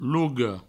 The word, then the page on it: loga: provençal; logo